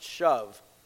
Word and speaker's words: shuv: As Walter Kaiser explains, the Hebrew word to return or to repent is pronounced shuv.